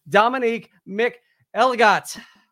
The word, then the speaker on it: Dominique McElligott: Please help me welcome Dominique McElligott.